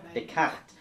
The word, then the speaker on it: Descartes: Han har ett S där i mitten, ja, ja, men så har han inte uttalat. Nej. – Descartes.